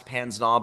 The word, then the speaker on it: pince-nez: It's spelled pince-nez, but it's pronounced pince-nez,